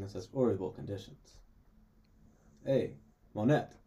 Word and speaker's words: Monet: It is Monet. – It's got a T on the end. – In French, the T is silent. – In French, you never pronounce the T.